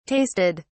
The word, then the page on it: tasted: British English